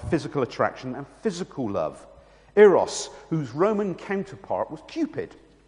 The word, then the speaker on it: eros: The first was eros, or as it's properly pronounced, eros.